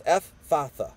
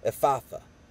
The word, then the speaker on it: Ephphatha: Ephphatha, I think is how you say that. F, and it's three syllables. Ephphatha. Ephphatha.